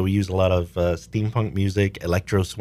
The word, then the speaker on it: steampunk: Is it steam engine? Am I saying it right? – Steampunk. Yeah.